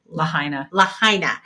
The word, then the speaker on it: Lahaina: So just this week, or I guess I should say last Sunday morning on August 20th, members of the, I've always pronounced this wrong, Lahaina.